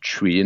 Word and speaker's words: Twi: And the language is called Chui, and it's pronounced T-W-I, and it's pronounced Chui.